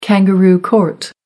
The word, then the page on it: kangaroo court: American English